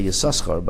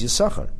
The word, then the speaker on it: Yisachar: And some people mistakenly say Yisachar, but they're not supposed to pronounce it Yisachar. If you read the Torah pronouncing it Yisachar, that's not appropriate. The name is Yisachar.